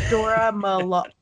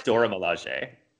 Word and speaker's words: Dora Milaje: Sorry. I don't know if I pronounced that right. Dora or Dorj Malahe? – I can never pronounce anything. – I'm embarrassed. – Dora Milaje. – I think it's Dora Milaje.